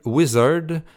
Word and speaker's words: Wizrd: Et ce sera suivi de la formation norvégienne Wizard, qui s'écrit W-I-Z-R-D, qui feront paraître le 6 décembre prochain leur second album qui a pour titre Elements.